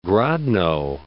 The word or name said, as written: Grodno